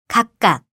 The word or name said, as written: 각각